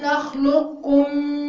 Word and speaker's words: نخلقكم: لا بصوا يا أخوات هنا القاف والكاف فيها إدغام. هتنطقيها قاف بس تمام؟ ألم نخلقكم من ماء مهين.